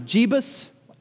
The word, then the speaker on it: Jebus: Is it going to be the town of Jebus? I'm not mispronouncing Jesus, that's what it's pronounced J E B U S.